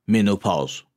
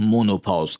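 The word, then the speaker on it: menopause: بهش میگن منوپاز که بعضیا به اشتباه مونوپاز تلفظ میکنن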